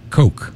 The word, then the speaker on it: Koch: The featured track, K-O-C-H is how you say it. That's all I got to say about that. Coke.